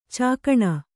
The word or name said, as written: ಚುಕ್ಕಣ